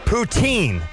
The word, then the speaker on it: poutine: Poutine. Is how you say that.